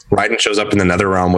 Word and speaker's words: Raiden: So Raiden at the end, Raiden is how you say his name in Mortal Kombat.